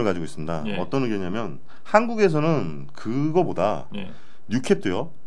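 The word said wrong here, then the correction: new crop: 예, 뉴크랍. 아 뉴크랍도요. 예. 아 죄송합니다. 자꾸 뭐 저는 캡이라고 발음을 해서 음.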